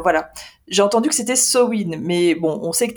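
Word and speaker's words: Samhain: On va le prononcer à l'irlandaise et ce sera Sowin.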